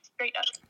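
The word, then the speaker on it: Strait Up: It's called Straight Up, but it's spelled like S-T-R-A-I-T, like the Bass Strait. Straight Up.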